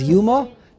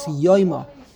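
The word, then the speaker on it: Yoyma (יומא): It's Yoyma. Grammatically, the correct grammatical way to pronounce the Masechta is Masechta Yoyma. In the Yeshiva, if you say Yoyma, they'll look at you like you're from Mars. They say Yuma.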